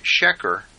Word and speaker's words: sheker: But the Hebrew word, sheker, you might pronounce it sheker, which actually sounds like sugar, is the word from which we get our word sugar.